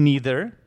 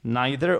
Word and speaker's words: neither: First of all, the simpler one is to say me neither. Yeah, that would have been the simpler way to say it. But also you can use neither or neither. The pronunciation doesn't matter. Both variants are acceptable. Neither, neither.